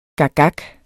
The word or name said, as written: gakgak